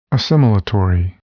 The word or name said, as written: assimilatory